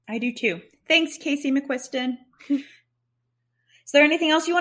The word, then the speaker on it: McQuiston: I think that McQuiston, am I saying that right? – Mm-hmm.